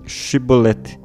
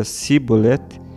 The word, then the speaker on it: Shibolete: Caso respondesse não, mandavam-lhe dizer Shibolete, que significa espiga, e se não pronunciava certo e dizia sibolete, agarravam-no e o degolavam nos vales do Jordão.